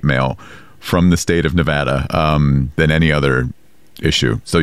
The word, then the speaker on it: Nevada: because a lot of politicians would say Nevada. – Wrong. – And that is wrong. – Wrong. It is Nevada.